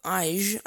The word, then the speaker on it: Ige: By the way, the governor of Hawaii is Dave Ige. I believe that's how you pronounce his name, I-G-E.